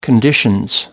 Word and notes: conditions: British English

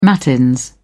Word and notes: matins: British English

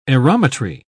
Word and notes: aerometry: British English

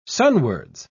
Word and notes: sunwards: British English

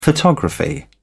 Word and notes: photography: British English

